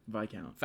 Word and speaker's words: Viscount: Viscount. – Viscount Lumley. They shouldn't have the S in there.